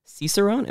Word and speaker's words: Cicerone: And, you know, along with owning ABC Beer Co., you're also a Cicerone. Am I saying that right? – You got it.